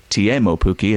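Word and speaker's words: Teemu Pukki: He has scored, Timo Pukki, or Tiemo Pukki, as he's told us it's now pronounced, he has scored in each of his first three Premier League games.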